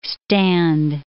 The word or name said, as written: stand